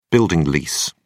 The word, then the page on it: building lease: British English